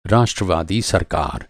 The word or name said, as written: राष्ट्रवादी